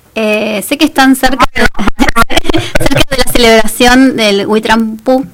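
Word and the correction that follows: We Tripantu: Huitripantru, así se pronuncia, porque viví en Chile y sé que se pronuncia así igual.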